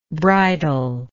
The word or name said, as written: bridally